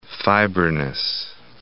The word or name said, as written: fibrinous